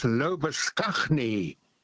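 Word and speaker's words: Labuschagne: Lobashane. – Oh, yes. – Now, Mr. Lobashane's name in South Africa, where I spent my youth, was and still is pronounced as Lobaskagni.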